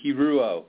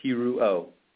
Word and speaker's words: Hirou: It's Jason Hirou. You're really close. Three syllables. Hirou.